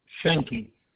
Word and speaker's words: Schenke: Richard Schenke. That's spelled S-C-H-E-N-K-E.